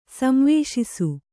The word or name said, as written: ಸಂವೇಶಿಸು